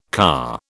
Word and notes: car: British English

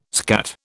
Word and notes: skat: British English

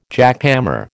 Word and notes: jackhammer: American English

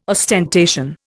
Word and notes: ostentation: Indian

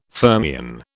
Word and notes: fermion: British English

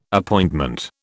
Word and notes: Appointment: British English

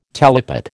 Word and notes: talipot: American English

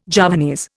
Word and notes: Javanese: Indian

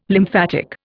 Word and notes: lymphatic: Indian

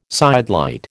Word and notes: sidelight: American English

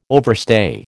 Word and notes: overstay: American English

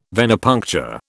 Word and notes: Venepuncture: British English

